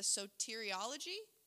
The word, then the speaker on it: soteriology: So this is the term of this is, right, the soteriology.